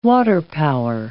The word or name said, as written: waterpower